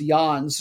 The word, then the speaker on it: Jans: Their captain's name is Laurent, I think it's going to be pronounced Jans, J-A-N-S, plays right back.